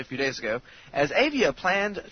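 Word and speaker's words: Avaya: Actually, it's called, it's spelled A-V-A-Y-A. I'm going to pronounce that Avia. Avia? Avia.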